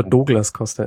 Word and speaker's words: Douglas Costa: Naja, da ist ja das kleine Problem, dass er es bei 3 Versuchen 3 Journalisten auf 3 Arten gesagt hat, wie man ihn aussprechen würde. Aber Douglas Costa ist es anscheinend.